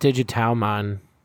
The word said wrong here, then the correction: Digitamamon: Digitamamon, that's what it is.